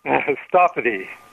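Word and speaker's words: Sthapati: I cannot pronounce his last name. Shasapati. Help me. – Stapati.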